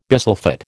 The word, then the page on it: bisulphate: American English